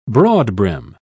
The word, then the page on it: broadbrim: British English